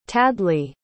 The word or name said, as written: tadleigh